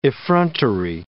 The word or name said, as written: effrontery